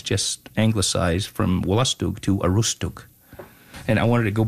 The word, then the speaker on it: Aroostook: And if you go further up the Wolustug, there's a branch that goes into the U.S., and it's still called Wolustug, but they don't pronounce it Wolustug. They call it Aroostook.